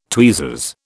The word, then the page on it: tweezers: British English